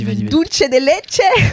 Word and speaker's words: dulce de leche: Dulce de leche.